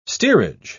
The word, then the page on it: steerage: British English